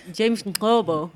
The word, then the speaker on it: James Nkobo: Hey, dat citaat van, hoe spreek je het uit? – James Nkobo.